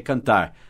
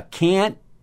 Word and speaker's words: can't: O T às vezes a gente nem pronuncia ou não solta o T, a gente só segura. Can't, mas é mais can't, can't. Pode falar can't, mas geralmente a gente não faz o T forte assim, can't.